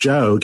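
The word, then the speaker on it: Geo: Joe is spelled G-O and pronounced Joe Gamow.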